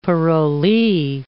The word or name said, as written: parolee